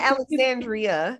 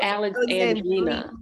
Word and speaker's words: Alexandrina: It's not Alexandria. – It's so Alexandrina. Yeah, Alexandrina. – Yeah, it's kind of cute.